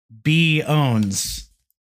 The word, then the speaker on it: pwns: But you're not supposed to pronounce the P, so it's pronounced B owns.